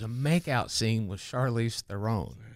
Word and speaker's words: Theron: People like to say Theron, but it's freaking Theron.